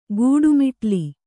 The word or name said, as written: ಗೂಡುಮಿಟ್ಲಿ